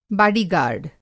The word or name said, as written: बाडी-गाई